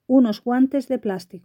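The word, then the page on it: unos guantes de plastico: Spanish (Spain)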